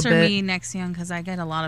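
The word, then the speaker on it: NXIVM: I think they actually pronounce it Nexium. I mean, it's a weird spelling, and they got...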